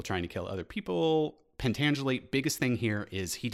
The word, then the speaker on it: Pentangeli: Pentangeli.